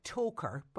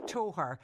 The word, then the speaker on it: Toher: On paper, it looks like Toker, but it's actually pronounced Toher.